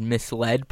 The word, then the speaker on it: misled: never heard the word misled pronounced and thought it was misled. – Yeah, yeah, it's a pretty good one because it makes sense. Misled sounds like being misled.